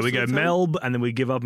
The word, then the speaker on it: Melbourne: And it's not I think a lot of sort of North American people do like a Melbourne, like they put emphasis on the second, on the born.